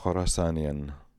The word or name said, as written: Khorassanian